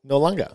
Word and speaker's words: Noarlunga: Noarlunga. – That's a very good pronunciation.